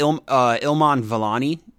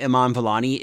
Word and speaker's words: Iman Vellani: And I think Iman Vellani. Yeah, sorry, I said that wrong. Iman Vellani is great as Ms. Marvel, Kamala Khan.